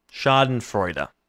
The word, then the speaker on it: Schadenfreude: It's Schnadenfreude. It's Schadenfreude. It's made up from the German words for damage and joy, and it means taking pleasure in the misery of others.